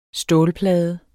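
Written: STÅLPLADE

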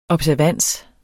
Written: observans

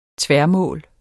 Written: tværmål

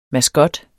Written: MASKOTTEN

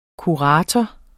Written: kurator

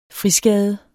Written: friskade

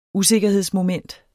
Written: usikkerhedsmoment

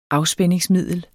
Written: afspændingsmiddel

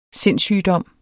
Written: sindssygdom

